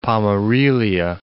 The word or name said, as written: Pomerelia